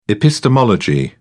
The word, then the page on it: epistemology: British English